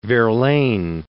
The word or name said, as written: Verlaine